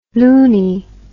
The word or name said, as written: luni